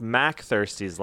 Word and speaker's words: MacThirsty's: I think that it's pronounced MacThirsty's Lounge, like Mac Aladdin.